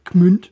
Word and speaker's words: Gmünd: Gerade zum Beispiel, wenn man sich das Wappen von Schwäbisch Gmünd, ja, das spricht man tatsächlich mit dem, auch wenn es mit einem G geschrieben wird, sehr mit einem sehr harten K, Gmünd, in Baden-Württemberg.